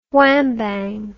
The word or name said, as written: whambang